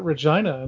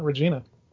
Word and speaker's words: Regina: So now, how impressive that you knew to pronounce it Regina and not Regina.